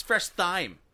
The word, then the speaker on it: Thyme: I said thyme, and you're like, that's not how you say it. – No. – It's Fresh Thyme.